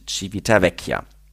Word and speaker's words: Civitavecchia: Das Schiff fährt immer samstags ab Genua mit Stops in Marseille, Syrakus, Tarent und Civitavecchia. – Ja, liebe Zuhörer, Zuhörerinnen, das war das erste Mal, dass Civitavecchia richtig ausgesprochen hat.